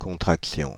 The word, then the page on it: contraction: France (Île-de-France); avec sigmatisme latéral; [kɔ̃.tʁak.ɬ͡sjɔ̃]